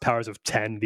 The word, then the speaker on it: Powers of X: I think it's pronounced Powers of 10. The X is supposed to be the Roman numeral. So House of X, Powers of 10, but it looks like House of X, Powers of X.